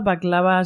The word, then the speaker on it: Backlava: Baklava se escribe BACKLAVA. O sea, como back de espalda y lava.